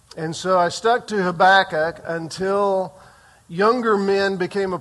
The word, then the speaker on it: Habakkuk: I grew up calling him Habakkuk.